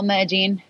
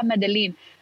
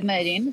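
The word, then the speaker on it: Medellín: So, so right now, Medellin, that's the real pronunciation. Everybody's like, ah, Medellin. But like uh, in, in, in Colombian Spanish, we call it Medellin.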